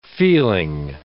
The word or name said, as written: feeling